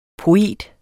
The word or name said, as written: POETERNES